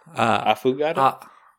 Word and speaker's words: affogato: How do you say it? Affogato. – No, affogato.